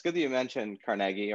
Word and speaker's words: Carnegie: And, you know, other than perhaps Andrew Carnegie, I always pronounce it that way, because that's what I heard, even though everybody says Carnegie Mellon. I always heard it was Carnegie. – It probably was Carnegie in the Scottish.